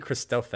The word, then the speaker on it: Christophe: I think maybe it might be Christophe because he's French, but it's basically Christopher without an R. That's how you say his name.